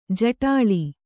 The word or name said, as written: ಜಟಲೆ